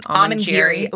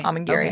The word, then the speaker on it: Amangiri: How do you say it again? – Amangiri. Like the sushi nigiri. – Okay. – Amangiri.